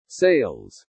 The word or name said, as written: SALES